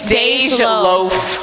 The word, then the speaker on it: Dej Loaf: Deflo, how you pronounce it? Is it Deflo? – Dej Loaf. – Dej Loaf.